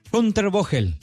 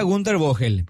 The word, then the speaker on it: Günter Vogel: Günter Vogel. – Hunter Vogel. – Ah, al revés. Hunter Vogel. – No, en realidad me explicaron porque así es un apellido alemán. Yo decía Gunter Vogel, pero él dice que la GH se lee como F, algo así, vaya.